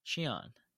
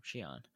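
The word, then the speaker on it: Xi'an: Shion. – That's how you pronounce her name. Shion.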